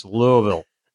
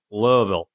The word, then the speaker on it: Louisville: He said it's Louisville. Like you want to Louisville. Move your mouth as little as possible. Louisville.